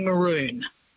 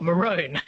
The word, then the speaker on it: maroon: So the original pronunciation, we know this quite clearly because we have people writing about pronunciation in the 16th and 17th centuries, which is when the word came in. It would have been maroon. – Right. – So much like those other words we swiped from French cartoon, balloon, saloon, pantaloon, etc. They're all pronounced with that way.